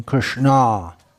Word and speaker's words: Krishna: Krishna is a female name, Krishna. Long A at the end.